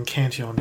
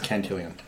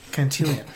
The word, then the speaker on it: Cantillon: And Cantillon is obviously, you've probably heard of it. It's quite a very well-renowned. – I think the pronunciation is Cantillon. – Cantillon. – No one say that ever again.